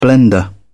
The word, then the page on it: blender: British English